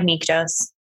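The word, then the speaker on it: Nyktos: Nyctos is how it's said in audible.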